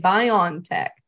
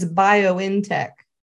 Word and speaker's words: BioNTech: They'll say BioNTech. I go, it's BioNTech. The N is capitalized. It's not, it's literally bio in technology.